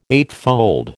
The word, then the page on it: eightfold: American English